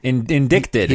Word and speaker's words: indicted: Indicted. – He was indicted. I was going to pronounce it exactly the same way, the correct way.